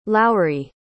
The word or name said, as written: lowrie